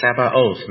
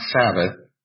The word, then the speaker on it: Sabaoth: It's Sabaoth, not Sabbath.